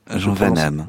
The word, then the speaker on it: Jean Van Hamme: Vous prononcez Jean Van Hamme ? – Jean Van Hamme. – Van Hamme, je prononce mal.